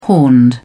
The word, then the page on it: horned: British English